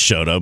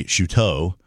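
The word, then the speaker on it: Chouteau: Well, I think she says Shodo, but it should be Shuto.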